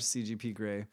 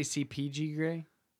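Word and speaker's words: CGP Grey: I always watch CGP Gray. In 4 minutes. – What did I say? Did I say CPG Gray? CGP. – I thought you said it right, but yeah, CGP.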